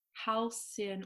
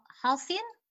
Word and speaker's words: Halcyon: This is called Halcyon, or how would you say that? – Halcyon? – Yeah, I would say a Sith, not a Kith.